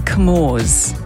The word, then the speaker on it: Comoros: Some people I know pronounce it as the Comores, the Comore Islands, which is in the Indian Ocean. And you spell that C-O-M-O-R-O-S, the Comores.